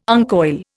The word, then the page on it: uncoil: Indian